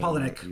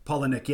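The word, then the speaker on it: Palahniuk: Polinick. – You're probably better at than I am. – Polinick.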